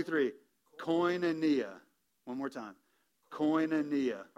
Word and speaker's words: koinonia: I'm going to try to make sure that I can say that correctly and help you say that correctly. But koinonia. All right, it's koinonia.